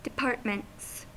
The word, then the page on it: departments: American English